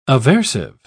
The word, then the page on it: aversive: British English